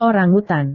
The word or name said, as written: orangutan